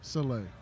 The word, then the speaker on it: Saleh: Saleh, whatever.